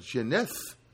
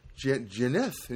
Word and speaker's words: Jeaneth: And it's spelled J-E-A, it's spelled unusual, J-E-A-N-E-T-H. And he said, that's why people got confused. And it's like Janeth. Janeth. And he said, no, the H is silent.